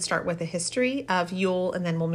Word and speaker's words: Yule: And it was originally called Jule, J-U-L, but I believe that is pronounced Yule anyway, and it takes place on the longest night of the year.